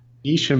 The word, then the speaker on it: Nysha: Is it Nisha or Nisha? I think it's Nisha. – Nisha makes more sense. N-Y-S-H-A.